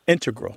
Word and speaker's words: integral: Yeah, but both correct, just to clarify here, both correct, but the mathematicians and math-using scientists are more likely to put, say, integral with the stress on the first syllable.